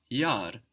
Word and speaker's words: Jahr: For example, the year is das Jahr, that's spelled J-A-H-R, and that H just stretches out that vowel, das Jahr.